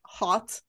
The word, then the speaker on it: haute: Haute, am I saying it right? Hot. – I think so.